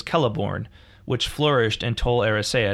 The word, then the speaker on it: Celeborn: There's two different Celeborns. One is a tree, and one is an elf. – But they're pronounced the same. – Yes, as far as I know.